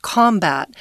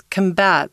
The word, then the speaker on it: combat: Combating this word is combat is a really fun word um, because if you say it as a noun, it's combat, but when it's a verb, it's combat. The accent goes on the on the second syllable, and that means to fight something.